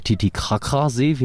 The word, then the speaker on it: Titicaca-See: Und wir haben halt dadurch gesehen, der Titicaca-See, wie man ihn ja richtig aussprechen sollte, Bolivien, Peru, der ist ja fast komplett umgekippt, der ist ja nahezu tot.